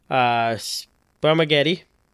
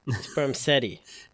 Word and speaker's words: Spermghetti: Spermageddy and the Gentleman Pirate. – Yes. Go to fact. – Sperm Seti. – No, it's spelled Getty. – It's spelled, I don't know. – S-P-E-R-M-G-H-E-T-T-I.